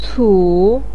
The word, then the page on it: cu3: pinyin